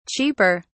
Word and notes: cheaper: British English

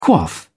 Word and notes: quaff: British English

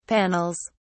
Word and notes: panels: British English

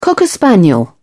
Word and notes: cocker spaniel: British English

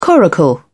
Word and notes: coracle: British English

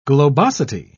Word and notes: globosity: British English

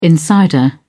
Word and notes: insider: British English

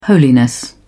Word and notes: holiness: British English

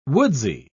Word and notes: woodsy: British English

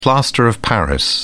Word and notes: plaster of paris: British English

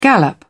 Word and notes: gallop: British English

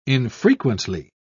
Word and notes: infrequently: British English

